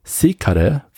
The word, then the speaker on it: secare: secare für schneiden verwendet. – Secare wird es ausgesprochen. Mhm. Vertrau mir, ich hatte Latein.